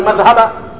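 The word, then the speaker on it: madhhaba (مذهبة): Neno madhhaba huwa bidhali almu'jama, linatamkwa kwa dhali yenye nukta juu yake. Fa fathil ha'i wa fathil ha'i, na kutiwa fatha ya he madhhaba, fatha ya he wal ba'i almuwahhada, na vile vile be inakuwa na fatha yenye nukta moja chini yake.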